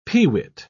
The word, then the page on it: pewit: British English